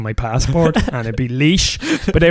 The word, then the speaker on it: Laois: It's Laois, which is very close to Laos and Laois, or Lao, depending on how you try to pronounce it.